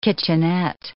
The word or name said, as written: kitchenette